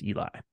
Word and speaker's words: Eli: And no big deal, but my first name is pronounced Eli.